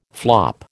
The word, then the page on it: flop: American English